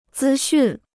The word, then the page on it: 资讯: zī xùn